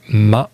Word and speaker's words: MHA: Elles sont en France, à Rennes plus précisément, pour y écouter deux groupes locaux, proches musicalement et géographiquement, Mermonte, puis Ma, ça s'écrit M-H-A. On commence avec Mermonte et la chanson Motorique. – Thank you. – C'était Ma, M-H-A, avec Le Temps.